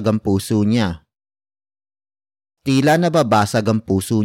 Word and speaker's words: puso: It is the focus marker that introduces the main topic of the sentence, which is puso. Puso. Puso. Puso is spelled as P-U-S-O.